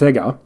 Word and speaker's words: Sega: And it's technically it's pronounced Sega because it is a portmanteau of service games. – Oh, okay. – S-E-N-G-A.